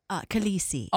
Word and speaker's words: Khaleesi: Khaleesi. – Oh, Khaleesi. K-H-A-L-E-S-S-I.